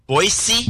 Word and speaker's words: Boise: That is the proper pronunciation, by the way. – Boise? – Boise, yes. – So you pronounce it kind of with an S.